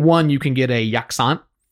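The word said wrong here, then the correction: sak yant: which is yaksan or sakyan? – Sakyan. – I knew I said it wrong.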